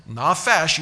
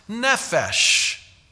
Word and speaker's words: nefesh: Nafash, you hear the vowels in there, but let's just keep the consonants the same, but we'll change what they call the vowel pointings so that it's now pronounced nefesh.